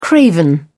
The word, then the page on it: craven: British English